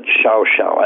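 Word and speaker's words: Llao Llao: And it's spelled LLAO-LLAO, but they pronounce it Xiao Xiao. I don't know how you do that, but that's Castellano Spanish down there.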